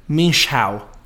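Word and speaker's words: mwynhau: I'll spell it for our listeners. M W Y N H A U. M W Y N H A U. Minshao.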